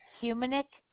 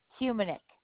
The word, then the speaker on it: Humenuik: And the last name is Humanic. That is HUMENUIK. Second U is silent, so it's pronounced Humanic.